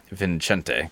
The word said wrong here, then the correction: Vicente: I put an extra N in there. It's Vicente.